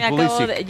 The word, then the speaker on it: Pulisic: Pues porque Pulisic, él ha pedido en entrevistas, en el anterior mundial, recuerdo, nos tocó cubrir Estados Unidos, y él, como tiene origen polaco, es la correcta pronunciación.